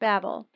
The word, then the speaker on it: Babel: Babylon is three Hebrew letters, Beit, Beit, Lamed, and is pronounced Babel.